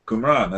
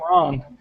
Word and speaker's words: Qumran: Is it Qumran? – Qumran, that's exactly right. – Qumran.